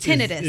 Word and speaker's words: tinnitus: Use tinnitus. Tinnitus. Is the way a audiologist or a physician would say.